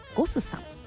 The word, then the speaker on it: GOSS: ゴスさん、 GOSS と書いてゴスさん、そしてお寿司屋さんが 2 店舗ございます。